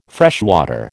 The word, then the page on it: freshwater: American English